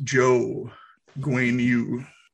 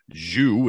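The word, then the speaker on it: Zhou Guanyu: It's not spelled this way, but it is pronounced Joe Guanyu. Spelled Z-H-O-U, but it's pronounced Joe. – One team is getting their points, and I'm very thankful for them that they showed me that the pronunciation is Joe instead of Zhu, which is what I was going to say.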